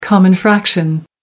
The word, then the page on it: common fraction: American English